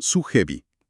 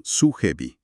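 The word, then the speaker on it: sujebi (수제비): Nuestro análisis de hoy se centra en una hoja de ruta técnica para un plato llamado sujebi, pronunciado sujebi. – Sujebi, exacto.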